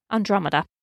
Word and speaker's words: Andromeda: Andromeda.